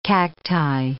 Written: cacti

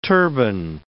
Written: turbine

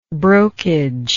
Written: brokage

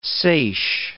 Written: seiche

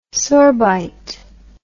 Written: sorbite

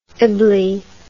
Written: ibly

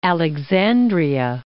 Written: Alexandria

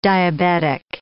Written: diabetic

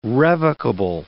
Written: revocable

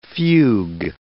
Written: fugue

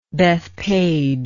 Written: Bethpage

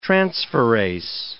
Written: transferase

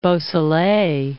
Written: Beausoleil

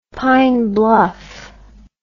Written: pine bluff

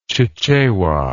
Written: chichewa

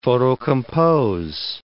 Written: photocompose

